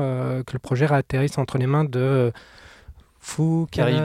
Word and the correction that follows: Joji Fukunaga: Joji Fukunaga.